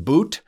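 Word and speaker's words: Bout: I believe Victor's last name is pronounced Boot. And also that he was born in Ubeki Beki Beki Stan Stan. So so it's pronounced Boot.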